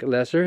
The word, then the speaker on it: Loesser: And continuing in that tradition, we're going to talk about Frank Lesser. His name is spelled L-O-E-S-S-E-R, one of the great Broadway composers.